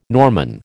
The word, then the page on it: Norman: American English